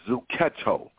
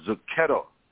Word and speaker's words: zucchetto: If you want to sound it out, it's like the ghetto. Like the ghetto. That sounds crazy. But it's called a zucchetto. – Mm-hmm. – A zucchetto. Okay? Mm-hmm. It's spelled Z-U-C-C-H-E-T-T-O. It's the covering of the head is to honor Jesus Christ as our head.